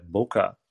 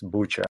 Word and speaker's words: Bucha: So in the beginning, you talked about, you said Buka. I'm afraid it's pronounced Bucha.